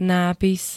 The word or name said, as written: nápis